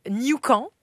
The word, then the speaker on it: Newcomb: Moi, j'avais Newcomb. Comme Yukon, mais qui avec un N.